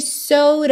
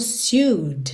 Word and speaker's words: sewed: Primeiro, essa pronúncia sewed, que não é intuitiva para essa escrita. Sewed. Ok? Então, normalmente você pensaria algo como sued, sued, mas é sewed, sewed, ok?